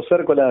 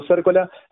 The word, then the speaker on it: Cércola: Entran a YouTube, ponen Nico Cércola, mi apellido se escribe con C, C-E-R-C-O-L-A, ponen Siendo Raíz, o ya en el canal de que tiene mi nombre, Nico Cércola, hay un montón de videos.